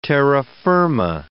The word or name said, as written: terra firma